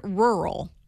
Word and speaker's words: rural: Like the word rural, okay? – You said that right.